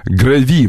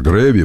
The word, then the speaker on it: Греви: Зебра Грави, мне говорят ударение на Грави. Вы знаете, когда я их фотографировал, Алекс, мой кенийский говорил все время Грави. Я думаю, кенийец, который меня возил фотографировать зебр Греви.